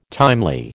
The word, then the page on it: timely: American English